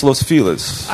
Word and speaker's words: Los Feliz: So is it Los Feliz? I've always been told it's Los Feliz. – I think the correct pronunciation is Los Feliz.